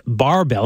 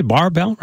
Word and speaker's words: Barrebelle: And also to give you guys a plug, it's Barbell. Am I saying that right, by the way? Barbell, right? – You got it.